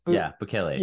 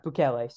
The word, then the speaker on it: Bukele: being willing to call Bukele. I don't know if that's actually how it's pronounced. – Bukele. Yeah. – Bukele.